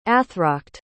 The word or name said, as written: athracht